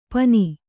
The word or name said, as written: ಪನಿ